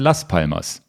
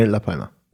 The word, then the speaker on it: La Palma: Ich dachte immer, ihr redet von der Stadt Las Palmas. Also La Palma wird das auch ausgesprochen, Las Palmas, ne? Oder spricht man das Las Palma aus? – Nee, La Palma.